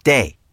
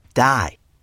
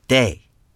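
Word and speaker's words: Dai: And it's Day. Excuse me? You said Di. People who knew him on the West Coast only pronounced it that way. But his true friends from the East Coast pronounced it Day.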